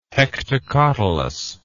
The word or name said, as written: hectocotylus